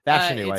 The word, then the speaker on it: Fashanu: Fashanu, I think. – Fashanu. So yes. Like fashion. Ooh, okay, that's easy. That's all you got to remember. It's like fashion. Fashanu, not Fashanu, not Fashanu, Fashanu.